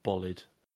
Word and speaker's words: Bolide: Apparently, it's the Bolide.